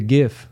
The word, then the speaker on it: GIF: And I added the GIF. Is that how you say it? – Yeah.